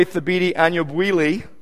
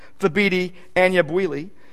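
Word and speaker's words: Thabiti Anyabwile: I like the way Thabiti Anyabwili, yes, that's how you say his name, Thabiti Anyabwili, says, conversion is a change so dramatic it requires the intervention of God the Holy Spirit.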